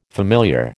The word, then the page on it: familiar: American English